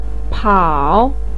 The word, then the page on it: pao: 3